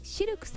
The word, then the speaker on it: CILQ: そして南青山にフレンチレストランシルクさん、